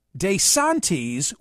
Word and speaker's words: DeSantis: Professor William Cannell, chair of Italian studies at Seton Hall University, told Axios that it is common for Italian Americans such as DeSantis to change the pronunciation of their name from the original Italian to a more anglicized version, but that DeSantis surprised him. This is the academic. DeSantis would be the proper Italian.